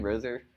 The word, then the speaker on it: Rozier: Just so many different contributors, whether it was Jaylen Brown or Terry Rozier. How do you pronounce his name? Rozier.